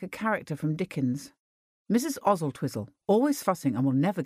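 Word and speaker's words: Oswaldtwistle: Oswald Twistle, pronounced Ozzle Twizzle.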